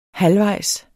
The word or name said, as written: halvvejs